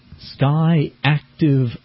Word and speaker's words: SKYACTIV: It's called the Sky Active 4, and it's spelled just as it sounds, SKYACTIV, four-cylinder engine.